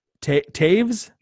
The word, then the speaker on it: Toews: Caleb's Bernie Taves? Is that how you say it? T-O-E-W-S? I don't know. – I think so.